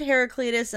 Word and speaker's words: Heraclitus: Regarding the Sarah's on my episode, you mentioned Heraclitus and the theory that you... or is that, I pronounced that wrong, didn't I? – No, you get it right, actually. – Oh, hell yeah. You mentioned Heraclitus and the theory that you can only cross a river once.